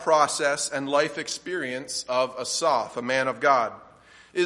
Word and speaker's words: Asaph: You'll notice at the top of the psalm, it says a psalm of Asaph, and that's how the Hebrews would have pronounced that. I'll probably fall into the easier pronunciation, but Asaph.